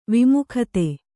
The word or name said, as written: ವಿಮುಗ್ಧ